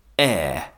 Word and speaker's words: heir: Air is a confusing word. It's spelt H-E-I-R and means the person who is going to inherit something important.